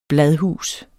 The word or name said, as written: bladhus